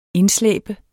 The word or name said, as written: indslæbe